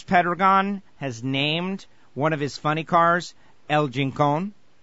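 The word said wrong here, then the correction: El Chingón: Am I saying that right? El Chincon. – El Chingon. – El Chingon. I feel like I'm saying it the same way you are. It just doesn't quite sound the same.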